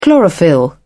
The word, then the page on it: chlorophyll: British English